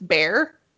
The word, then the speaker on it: Bexar: It looks like Bexar, but it's Bear.